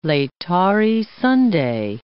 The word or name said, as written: Laetare Sunday